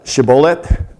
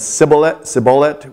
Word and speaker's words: shibboleth: But the word is shibboleth. And the SH sound at the beginning is hard to say. And we know that even in the modern day, people have a lisp sometimes and can't say certain kinds of S sounds. And so it sounds like people's pronunciation of that, some would have said sibboleth with an S sound and some with an SH sound.